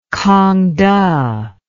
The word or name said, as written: kangte